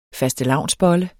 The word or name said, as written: FASTELAVNSBOLLEN